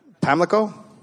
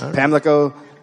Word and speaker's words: Pamlico: Blackbeard's body was thrown into, I'm going to try this, Pamlico? Yeah. All right. Well, that one you actually did the way it's spelled. – All right. – No S's. Pamlico Sound?